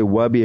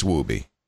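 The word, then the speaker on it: Wubi: I've heard people say Wubby. I've heard people say Woobie. No, it's Woobie.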